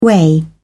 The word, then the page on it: weigh: British English